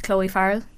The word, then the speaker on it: Chloe Farrell: That my name is Chloe Farrell.